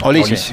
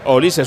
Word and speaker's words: Olise: O para él es Olise, su nombre, porque Olise, es de pronunciación inglesa, bien, no Olise. Francesa.